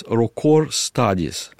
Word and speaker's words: Rocor Studies: Называется, у него немножко сложное название, оно именно в его адрес Rocor Studies, Rocor Studies, это будет R-O-C-O-R, Studies, S-T-U-I-D-E-S, Rocor Studies.